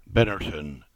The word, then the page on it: Binnerton: English